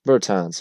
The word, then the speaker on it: Bertans: And Bertans. That's how you pronounce.